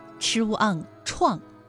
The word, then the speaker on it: 怆: 它的主要读音呢是四声 ch u an 怆，表示伤感难过的样子，比如说诗中所用的独怆然而涕下。